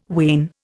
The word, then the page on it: wane: Indian